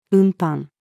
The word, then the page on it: 運搬: female